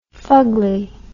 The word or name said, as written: fugly